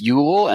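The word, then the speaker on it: Jöl: It's Yule, and that's spelled J O with the dot, double dot above it.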